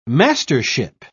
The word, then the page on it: mastership: British English